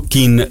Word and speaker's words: Kean: Siccome mi sono informato da amici inglesi come si pronuncia Kean, tutti mi hanno detto Kin. Lui ha precisa domanda, ha detto due volte Kin, una volta Ken.